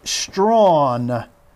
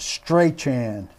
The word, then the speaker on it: Strachan: Um, wide receiver Mike Strawn, though it looks like it should be pronounced Strachan.